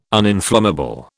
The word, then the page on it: uninflammable: British English